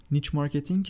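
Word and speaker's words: niche marketing: نیچ مارکتینگ که تلفظ صحیحش اینطوریه. – نیچ مارکتینگ.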